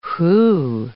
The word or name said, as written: whew